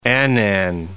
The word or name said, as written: Annan